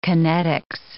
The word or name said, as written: kinetics